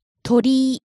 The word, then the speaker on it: 鳥居: 鳥居、鳥居。